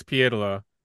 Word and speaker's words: Pietila: But what happened that year was Grandpa called Dirk and said, "It's Pietila. Don't say it like that. They're in Houghton. That's how you say it here."